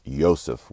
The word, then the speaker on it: Yosef: It comes straight from the Hebrew, also pronounced Yosef with a Y sound at the beginning.